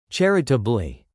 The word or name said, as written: charitably